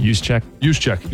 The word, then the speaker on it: Juszczyk: Because Juszczyk, how do you say his last name? – Juszczyk. – Yeah, I was close.